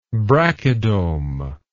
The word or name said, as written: brachydome